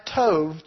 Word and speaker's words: Tob: Jephthah fled from his brothers and ended up in a land called Tob, which is pronounced Tov, T-O-V, Tov.